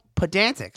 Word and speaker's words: Pedantic: then check out Pedantic. I said that right, right? – Yes.